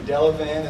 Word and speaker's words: Delavan: I believe, Delavan is the proper pronunciation.